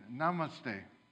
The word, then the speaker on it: namaste: It's on the screen, namaste.